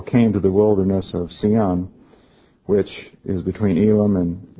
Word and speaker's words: Sin: I was actually looking at this word in one translation that's spelled Z-I-N, and this one it's S-I-N. It's actually pronounced, from the reference I looked at, as Sion. And it looks like it would be when you look at it compared to Sinai.